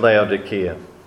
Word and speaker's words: Laodicea: And my apologies, I was used to say Laodicea, but I've been corrected just by going back to the text. In English, the C can be a hard C or a soft C, but in Greek it's the letter K, so it's obviously hard.